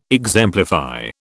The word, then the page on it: exemplify: British English